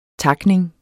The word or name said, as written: takning